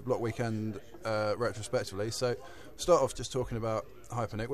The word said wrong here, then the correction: Hyponik: Is it Hypernic or Hyponic? – Yeah, Hyponic, H-Y-P-O-N-I-K.